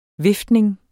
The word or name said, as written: viftning